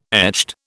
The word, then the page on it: echt: British English